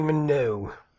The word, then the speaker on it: Minogue: Kylie Minogue.